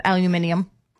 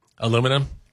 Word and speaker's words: aluminium: No, it's my mom used to extrude aluminium, and that's how they would pronounce it in India.